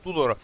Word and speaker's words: Tudor: Comunque Tudor deve, così è la pronuncia esatta,